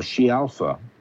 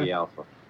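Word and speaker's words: Scialfa: No, She Alpha is the way you'd say it. Or is it Ski Alpha? – Ski Alpha. – She pronounces Ski Alpha.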